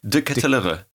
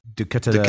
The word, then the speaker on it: De Ketelaere: De Ketelaere. – De Ketelaere. – De Ketelaere.